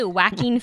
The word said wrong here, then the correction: Joaquin: laughs> How do you say it? – Joaquin. – Oh, Joaquin.